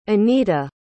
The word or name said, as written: aneta